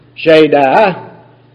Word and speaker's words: Shaddai: Shaddai, or pronunciation guide, Shaddai.